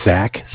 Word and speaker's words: Zach: My name is Zach, spelled Z-A-C-H, and I'm just giving a quick follow-up call here.